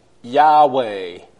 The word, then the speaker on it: Yahweh: Most scholars today believe that that name would have been pronounced Yahweh. But again, this is a theoretical pronunciation. There's nothing wrong with saying Yahweh. But again, we don't know if Yahweh is the actual pronunciation. It's a theory.